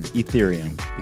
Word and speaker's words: Ethereum: Like ether? Like Ethereum. Nas and Jay-Z. Ethereum. – Ethereum. – Ethereum. Exactly. Okay.